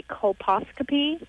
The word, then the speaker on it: colposcopy: I just got a colposcopy.